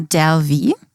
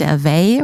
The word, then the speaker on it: Delvey: Ja, also die Amerikaner sagen Anna Delvey. Delvey, nicht Delvey oder so.